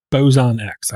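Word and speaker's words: Boson X: It's supposed to be like Higgs boson, Bosun X. I got to say it like that.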